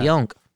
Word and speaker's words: Pionk: Truba was a huge trade, and yeah, they got some assets, but the big one has to be Pionk. Am I saying that right? – Yeah. – Pionk.